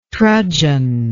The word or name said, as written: trudgen